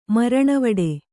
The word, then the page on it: ಮರಣವಡು: Kannada